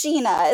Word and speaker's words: Jeanna: Uh, it's Gina.